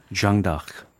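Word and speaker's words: Jeanne d'Arc: 불어 발음은 어떻게 하는지 궁금해요. 한번 좀 있어 보이게. – 창피한데. – 고급지게 한번 좀 해주세요. – 부끄러운데. – 아니, 괜찮아. – 주앙다크. – 주앙다크.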